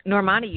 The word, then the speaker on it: Normani: All right, so number 2 was Simone and number 1 was Normani. Did I say that right? – Normani, you got that right.